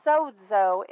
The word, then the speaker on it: sozo: And I just kept hearing the word sozo in my spirit. Sozo is the way it's pronounced. But it's actually, the Greek word sozo is spelled S-O-Z-O. S-O-Z-O.